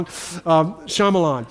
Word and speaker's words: Shyamalan: I used to, for the longest time, say Shyamalama Ding Dong, Shyamalan.